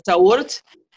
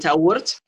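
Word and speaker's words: Taweret: So the first part of your name, according to the way that it's spelled, because Tawurt, as we pronounce her name, Tawurt, she is the hippopotamus.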